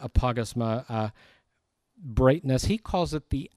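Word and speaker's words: Apagasma (ἀπαύγασμα): Apagasma is the Greek word. Apagasma. I think I wrote it on your outline, apagasma. The emphasis is, the accent is on the AU part of that word.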